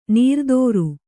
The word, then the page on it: ನೀರ್ದೋರು: nīrdōru